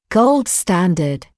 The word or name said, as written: gold standard